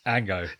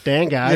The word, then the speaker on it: Django: It's a silent J. – Ango. – Well, Dango.